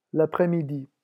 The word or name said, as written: l'apres-midi